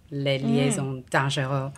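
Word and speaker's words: Les Liaisons Dangereuses: But it was the premise that hooked me, because it's a retelling of Dangerous Liaisons, or Les Liaisons Dangereux. – Excellent pronunciation.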